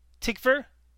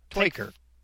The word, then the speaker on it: Tykwer: Tell me the T-Y-K-W-E-R. Tickver? – Twyker. – What? – Twyker. Twyker. – T-Y-K-W-E-R? – Twickwer. Twickwer. Yeah.